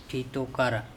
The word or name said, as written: Thitokara